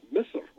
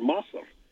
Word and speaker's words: Misr: Well, Egypt is really interesting because in Egyptian Arabic, it's Egypt is pronounced Misr or Masr. M-I-S-R in classical Arabic and M-A-S-R in colloquial Arabic.